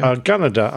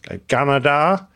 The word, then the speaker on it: Gunnedah: Is that the way you pronounce that? Gunnedah? Australia? – Gunnedah, I would say, personally. Okay. I just have to put an Australian accent on. Gunnedah.